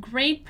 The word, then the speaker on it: great: Here you may have noticed that we have a word that has the letter E and the letter A together and is not pronounced the same as the ones we have seen so far, right? Great is pronounced differently.